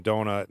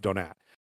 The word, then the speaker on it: Donat: They were having some fun with that on the podcast and calling him Donut. And I think they found like five or six different pronunciations, but I think it's Donat.